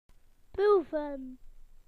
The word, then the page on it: to taste: British English